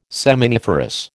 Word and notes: seminiferous: American English

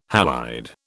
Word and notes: halide: British English

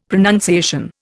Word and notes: pronunciation: Indian

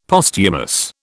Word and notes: Posthumous: British English

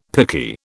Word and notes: picky: British English

